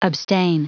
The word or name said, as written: abstain